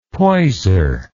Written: poiser

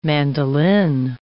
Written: mandolin

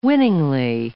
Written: winningly

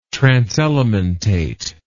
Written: transelementate

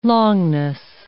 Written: longness

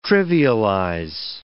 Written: trivialize

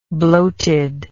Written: bloatedness

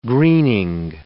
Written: greening

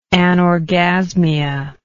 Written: anorgasmia

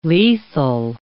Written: lethal

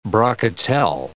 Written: brocatelle

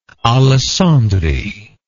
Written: alessandri

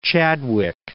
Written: Chadwick

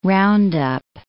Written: roundup

